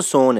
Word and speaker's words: سوهان: گز که خب همه میشتاسن، اما سوهان تو اصفهان خیلی سوهان تلفظ نمیشه، بهش میگن سون. تکرار میکنم، همون قضیه هس که کشیده میشه، سوهان تلفظ میشه سون.